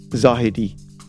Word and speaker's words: Zahedi: You know, the real pronunciation is Zahidi.